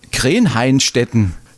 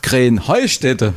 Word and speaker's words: Krähenheustätte: Ja, ihr sagt immer Krähenhainstetten. Und dabei heißt es doch Krähenheustätte, oder wie? – Krähenheustätte. – Ja, genau, und das ist die Betonung auf dem Heu.